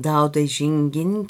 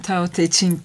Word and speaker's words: Dao De Jing: Evet, bugün binlerce yılın en sihirli sözcüklerinden Dao De Jing'in kitabı üzerine konuşacağız. Evet. Doğru telaffuz ettim mi? – Evet, siz doğrusunu söylediniz. Ben gerçi konuşurken yanlış olanı söylüyorum. Tao Te Ching diye.